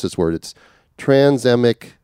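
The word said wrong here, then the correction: tranexamic: acid. TranexMic acid.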